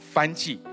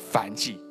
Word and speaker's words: 燔祭: 有些会念成燔祭，其实是念燔祭。 OK，这是刚刚早上 Sabrina 跟我说的燔祭。耶和华清早起来准备好了驴，劈好的献燔祭的柴，带着两个仆人和他的儿子以撒动身前往上帝指示他的地方。